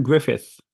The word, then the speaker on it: Gruffudd: But yeah, so it is, yeah, Ioan, and I guess the U's in his surname are pronounced as I's, and if for the D's. Anyway, but that is the correct pronunciation. Ioan Gruffudd.